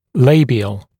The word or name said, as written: labial